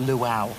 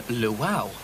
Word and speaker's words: luau: Oh, what, the Luwawa? – Yeah, I think you'll find it's pronounced Luau. – Yeah, but it's spelt Luwa-uwa. – But it's spoken Luau. It's the Hawaiian pronunciation.